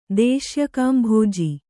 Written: ದೇಶ್ಯಕಾಂಭೋಜಿ

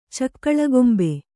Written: ಚಕ್ಕಳಗೊಂಬೆ

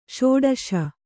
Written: ಷೋಡಶ

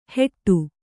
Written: ಹೆಟ್ಟು

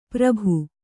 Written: ಪುರ್ಬು

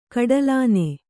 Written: ಕಡಲಾನೆ

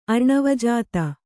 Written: ಅರ್ಣವಜಾತ

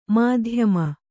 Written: ಮಧ್ಯಮ

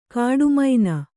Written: ಕೋಟೆಮನೆ

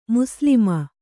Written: ಮುಸ್ಲಮ

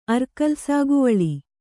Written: ಅರ್ಕಲ್‍ಸಾಗುವಳಿ

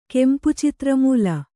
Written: ಕೆಂಪುಚಿತ್ರಮೂಲ

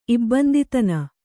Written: ಇಬ್ಬಂದಿತನ

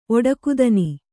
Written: ಒಡಕುದನಿ

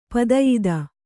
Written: ಪದಯಿದ

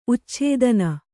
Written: ಉಚ್ಛೇದನ

